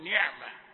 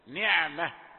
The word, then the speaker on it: نعمة: في نطق كلمة نعمة حرف العين أحيانا تجد هذا الحرف يقترب من الغين، يعني إذا ضغطت أو أهملت شيئا بسيطا تقول نعمة نعمة، يعني يأتي المخرج كأنه قريب من الغين.